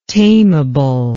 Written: tamable

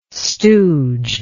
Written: stooge

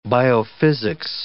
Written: biophysics

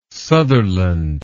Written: Sutherland

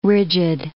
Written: rigid

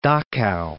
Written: Dachau

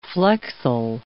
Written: flexile

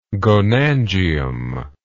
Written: gonangium

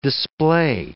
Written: display

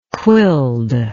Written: quilled

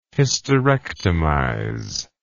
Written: hysterectomize

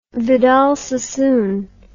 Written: Vidal Sassoon

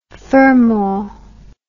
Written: Fermor

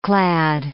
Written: clad

